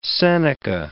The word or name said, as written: Seneca